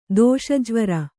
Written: ದೋಷಜ್ವರ

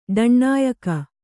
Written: ಡಣ್ಣಾಯಕ

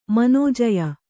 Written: ಮನೋಜಯ

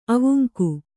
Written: ಅವಂಗ

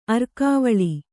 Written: ಅರಘವಾಳ

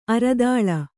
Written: ಅರಿದಲ

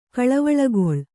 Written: ಕಳವಳಗೊಳ್

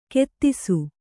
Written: ಖೇದಿಸು

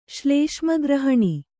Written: ಶ್ಲೇಷ್ಮಗ್ರಹಣಿ

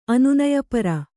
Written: ಅನುನಯಪರ

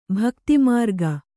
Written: ಭಕ್ತಿಮಾರ್ಗ

